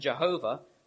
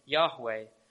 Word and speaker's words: Yahweh: So when it's connected with other names that we find in the Old Testament, it's not pronounced as j, as in Jehovah, but rather it's pronounced as ya, as in Yahweh.